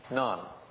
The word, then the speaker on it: Nantes: Finally, the Edict of Nantes, N-A-N-T-E-S. How's my French? I don't think that's the way it should be pronounced in French. Can somebody tell me how it should be? – One syllable. – Nantes? None. All right. Thank you. Thank you. N-A-N-T-E-S.